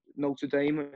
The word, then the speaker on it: Notre Dame: Like, we spent the most time out of everywhere on that tour in Notre Dame.